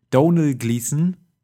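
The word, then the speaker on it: Domhnall Gleeson: Donald Gleason heißt Donald, Gleason. Gleason.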